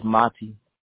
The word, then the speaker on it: Mati: Hi, Maddie. Am I correct? Am I pronouncing your name correct? – No, it's Mati.